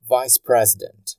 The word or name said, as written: vice president